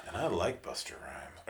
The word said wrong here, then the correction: Busta Rhymes: Or, excuse me, I like Busta Rhymes. Mm-hmm. Gotta say his name right.